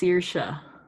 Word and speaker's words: Saoirse: Okay, apparently it's pronounced Saoirse. – Oh, fuck. Okay, Saoirse? Saoirse.